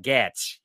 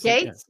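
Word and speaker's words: Gaetz: How do you say it? – Gatz. It's Gates. – Like, yeah. Gatz. It's Gates is the real way to say it.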